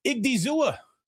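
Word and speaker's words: Odighizuwa: Igdizua. You pronounce the diggy. You got to get that Iggy in there.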